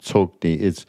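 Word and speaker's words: Tsoknyi: I'm incorrectly probably pronouncing his name, Tsokni. You're leaving out the harsh K, I think.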